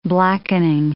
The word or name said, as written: blackening